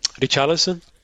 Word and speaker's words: Richarlison: Richardson? I'm terrible at it. – Richarlison? – Yes.